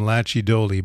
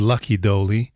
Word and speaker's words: Lachy Doley: Um, I used to pronounce his name Lachy Doley, but apparently it's Lucky Doley.